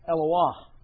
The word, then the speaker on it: Eloah: And so, most scholars then are inclined to think of Elohim as the plural form of Eloah, because of that long O sound right in the midst of that word.